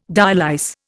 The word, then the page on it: dialyse: Indian